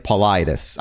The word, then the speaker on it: Paulides: His last name is Paulides. David Paulides. I kept saying Paulides because it's just how it's phonetically spelt, and that's my own brain doing its own thing.